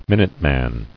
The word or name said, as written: Minutemen